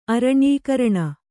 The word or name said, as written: ಅರಣ್ಯೀಕರಣ